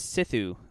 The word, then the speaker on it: Sithu: His name, uh, well, I found him because his, his first name is Sithu, which sounds like Sith.